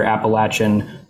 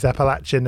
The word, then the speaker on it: Appalachian: Appalachian cultural region still. – Okay, that's interesting. I've heard that pronounced two different ways, Appalachian and Appalachian.